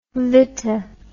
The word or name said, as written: Viti